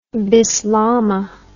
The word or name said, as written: bislama